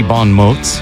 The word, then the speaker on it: bon mots: You'll hear her voice from time to time chiming in with witty bon mots.